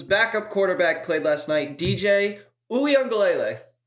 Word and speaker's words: Uiagalelei: Uiagalelei. It's not that hard. It's like you're saying ukulele, but it's like Uiagalelei? – Uiagalelei.